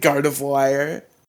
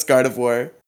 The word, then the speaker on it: Gardevoir: name's weird in general, but I thought it was Guard of Wire. – No, it's Guard War. Certain.